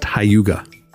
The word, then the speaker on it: hygge: It's it's spelled H Y G G E, but it's pronounced Hayuga.